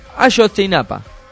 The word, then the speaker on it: Ayotzinapa: Si es mexicano, se debe pronunciar de una manera más fluida. Ayotzinapa.